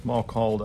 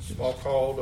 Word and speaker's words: Smalcald: There's the Articles of Smalcald. Is that how you pronounce that? – Smalcald, I think. – Smalcald, 1537.